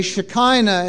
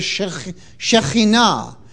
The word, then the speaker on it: Shekhinah: And a typical Christian would say Shekinah instead of Shekhinah.